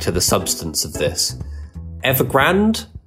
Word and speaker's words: Evergrande: Historically, I went with Evergrande, but Evergrande seems to have much more of a dramatic flourish to it. So I think I'm going to put the accent on the E going forwards.